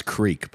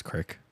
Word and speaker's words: creek: It's pronounced Creek, but your people say it as Crick.